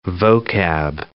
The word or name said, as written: vocab